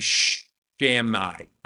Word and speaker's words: Shammai: The other was Rabbi Shammai. I think that's how you say it. S-H-A-M-M-A-I. Shammai, same thing.